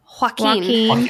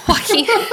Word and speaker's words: Joaquin: Is that a joke or is that how you really say it? Like, because that's not how you say his name. – I don't know. How are you supposed to say it? – Joaquin. – Oh, yeah, duh.